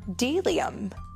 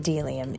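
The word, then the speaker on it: bdellium: We doubt anyone knows what delium is, but it's the only word dumb enough to begin with a silent B.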